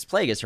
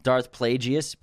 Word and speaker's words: Plagueis: It's Plagueis, right? – Plagueis, Dark Plagueis, Darth Plagueis.